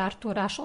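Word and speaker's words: Arthur H: To u stvari, on se zove Artur Ižlen, ali na francuskom se to izgovara Artur Aš, odnosno Artur H.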